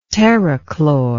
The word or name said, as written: terrachlor